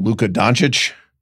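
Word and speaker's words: Luka Dončić: Our updated NBA mock draft, Kevin O'Connor's piece on Luka Doncic.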